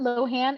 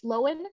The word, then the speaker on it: Lohan: It's not Lohan, it's Lohan. – What? – He came out on Instagram. Pull it up. She came out on Instagram and she said, yeah, my name's not Lohan, it's Lohan.